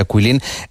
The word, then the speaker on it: Guilin: Bueno, esta ciudad de Kuilin, por si alguien la busca en internet, se escribe G-U-I-L-I-N, como si fuera Kuilin, pero se pronuncia Kuilin.